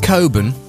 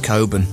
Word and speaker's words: Cockburn: I think it's pronounced Coburn.